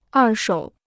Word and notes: 二手: èr shǒu

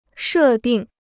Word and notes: 设定: shè dìng

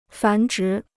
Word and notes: 繁殖: fán zhí

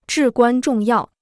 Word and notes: 至关重要: zhì guān zhòng yào

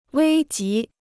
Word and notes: 危急: wēi jí

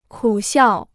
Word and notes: 苦笑: kǔ xiào

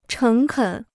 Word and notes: 诚恳: chéng kěn

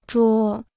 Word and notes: 捉: zhuō

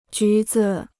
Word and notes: 橘子: jú zi

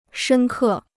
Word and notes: 深刻: shēn kè

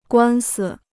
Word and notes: 官司: guān si

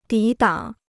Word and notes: 抵挡: dǐ dǎng

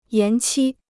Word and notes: 延期: yán qī

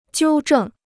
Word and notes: 纠正: jiū zhèng

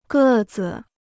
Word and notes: 个子: gè zi

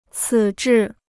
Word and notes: 此致: cǐ zhì

